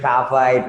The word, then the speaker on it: TAFA: You know, I, I I thought, you know what, it's TAFA, it rhymes with FIFA, and I'm like, you know what, let's go with it.